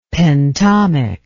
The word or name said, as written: pentomic